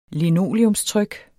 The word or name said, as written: LINOLEUMSTRYK